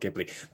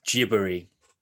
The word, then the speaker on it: Ghibli: However, because in Japanese, the soft G is not necessarily a word a sound they use in their phonology. So Ghibli would be how they pronounce that word. And so it became Ghibli from that.